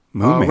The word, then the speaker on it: Moman: Is that it? Moman? – Moman. – Rhymes with Roman.